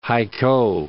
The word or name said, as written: Haikou